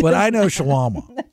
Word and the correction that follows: Shawana: You can't even say it right. – What did you just call my friend? – Tamisha. – Not her name. Her name is Shawana.